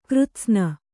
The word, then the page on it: ಕೃತ್ಸ್ನ: křtsna